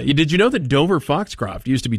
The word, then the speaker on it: Dover Foxcroft: Dover Foxcroft is the town. – Yes. – It was once pronounced Dover Foxtrot. By yours truly.